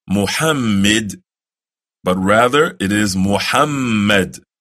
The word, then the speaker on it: Muhammad: O Allah, send prayers on Muhammad and on the family of Muhammad as you sent prayers on Ibrahim and on the family of Ibrahim.